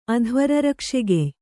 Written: ಅಧ್ವರರಕ್ಷೆಗೆಯ್

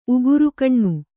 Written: ಉಗುರುಗೊನೆ

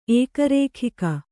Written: ಏಕರೇಖಿಕ

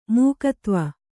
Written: ಮೇಘಾಧ್ವ

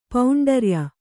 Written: ಪೌಂಡರ್ಯ

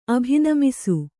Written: ಅಭಿನಮಿಸು

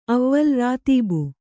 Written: ಅವ್ವಲ್ ರಾತೀಬು

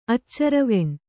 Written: ಅಚ್ಚರವೆಣ್

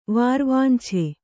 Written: ವಾರ್ವಾಂಛೆ

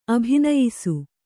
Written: ಅಭಿನಯಿಸು

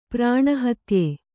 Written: ಪ್ರಾಣಹತ್ಯೆ

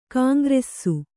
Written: ಘೀಂಕರಿಸು

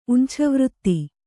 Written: ಉಂಛವೃತ್ತಿ

